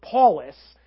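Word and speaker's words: Paulus (Παῦλος): Paul is a Greek name, and in Greek, the name would be pronounced Paulus.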